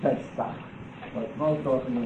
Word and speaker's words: Pesach: But Passover, Pesach, in Hebrew it's called Pesach. Or P-S-A-H, some spell it that way, Pesach, modern Hebrew, based upon the spelling in the Encyclopedia Judaica. The Ches sound, the Hebrew is written as an H in English, with a little dot on it.